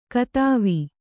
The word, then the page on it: ಕತಾವಿ: katāvi